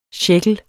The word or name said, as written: sjækkel